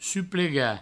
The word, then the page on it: suplegar: provençal; suplega